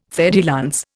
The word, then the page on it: fer de lance: Indian